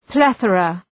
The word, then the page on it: plethora: ‘pleɵərə